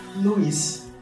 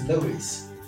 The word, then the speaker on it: Luiz: My son spells his name L-E-W-I-S. You spell yours L-U-I-Z. – Yes. – How do you say it? – Luis. Well, in Brazil, it's Luis. – So you're from Brazil? – I'm from Brazil. Yeah, but here it's Luis. But it's Luis.